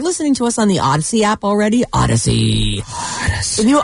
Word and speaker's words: Audacy: All this week, all you have to do is log into your Odyssey account. – Odyssey. – If you don't have the app, it's free. It's spelled A-U-D-A-C-Y, like think audio. Audacious. Odyssey.